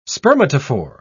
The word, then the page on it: spermatophore: British English